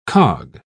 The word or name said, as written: cog